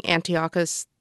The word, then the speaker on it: Antiochus: The Jews of Judea and Samaria are not having a great time with their king, Antiochus IV.